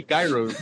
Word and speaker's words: gyro: No, it's gyro. Gyro? – No, it's a gyro. That's how you pronounce it. Gyro. – Gyro. Wait. – Yeah, you pronounce it gyro. Some shit like that. You don't pronounce the G.